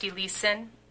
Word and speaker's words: Elieson: I think it's pronounced Eliason. – Eliason, yeah, Dale or Eliason, that's correct, E-L-I-E-S-O-N.